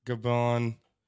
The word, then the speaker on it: Gabon: And then others are Qatar and Indonesia, Libya, United Arab Emirates, Algeria, Nigeria, Ecuador, Gabon, that's how you say it, Angola, and then Equatorial Guinea and the Congo.